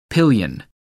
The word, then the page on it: pillion: American English